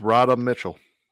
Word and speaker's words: Radha Mitchell: It's just Radha Mitchell. Simple as that, Radha Mitchell.